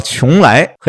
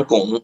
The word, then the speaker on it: 邛崃: 叫邛崃，这几个字你怎么样？你你认不认？ – 我弄不好就得念成巩。